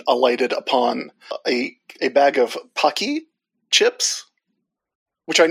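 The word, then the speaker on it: Paqui: And I made sure to check the Pocky YouTube channel before we started recording just to make sure we were saying it right.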